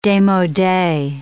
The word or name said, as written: demode